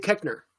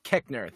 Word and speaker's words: Koechner: Oh, Dave Koechner. – Koechner.